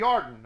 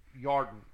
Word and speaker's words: Yarden: And the end point of the border was at the Yarden, that's the Jordan River. It's spelled with a Y in Hebrew, Yarden, Y-A-R-D-E-N.